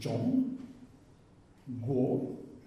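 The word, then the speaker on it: Zhong Guo (中国): Le milieu, c'est le Zhong. Guo.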